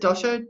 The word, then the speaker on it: dosha: That dosha, am I saying it right? – Yeah.